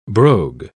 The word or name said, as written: brogue